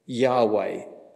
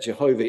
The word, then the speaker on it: Yahweh: But if you look at the Old Testament Hebrew, the name of God is as close as we can put it in English, Yahweh. Although some versions of the Bible translate it as Jehovah, but we believe there is significant evidence to suggest that Jehovah is not the correct pronunciation.